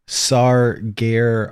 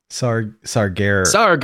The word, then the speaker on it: Sargeras: By the way, Wowpedia says that it's pronounced Sargeras. Sargeras.